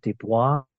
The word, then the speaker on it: Desbois: And this is Father Patrick Desbois. Mm. Uh, so I'll spell that out because it's French pronunciation D E S B O I S.